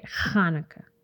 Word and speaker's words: Hanukkah: That's how you say it, Hanukkah.